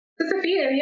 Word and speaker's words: Sisyphean: Sisyphean? Am I saying that right? Sisyphean? – Sisyphean, yeah.